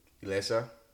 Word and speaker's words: Elessa: So today I have a great friend of mine in the building, Elessa. Did I say her name right? – Yeah, what's up? I did it. – Because yeah. It's a special name. – Yeah, it's not spelled like Alyssa.